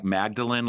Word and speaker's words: Magdalen: It's pronounced, I mean, it's written like Magdalene, like Mary Magdalene, but you pronounce it Magdalen.